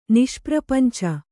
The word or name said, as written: ನಿಷ್ಪ್ರಪಂಚ